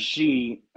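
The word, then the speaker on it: Qi: You know, I think it's pronounced G, and the chef, Q-I for those watching right now, but it's pronounced G, excuse me.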